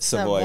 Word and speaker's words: Savoy: How would you like us to pronounce it? – Savoy would be sufficient.